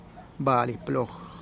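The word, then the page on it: bali plog: Teotitlán del Valle Zapotec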